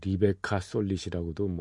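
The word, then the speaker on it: Rebecca Solnit: 어, 이 내용은 예, 최근에 나왔던 어, 레베카 솔렛인가요? 뭐 리베카 솔릿이라고도 뭐 예, 원어로 발음하는 사람도 있습니다만 아, 레, 베카 솔릿이 쓴 책이요 있어요.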